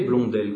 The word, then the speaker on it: Blondel: Et en effet, Blondel, comme ça se prononce, hein, B-L-O-N-D-E-L,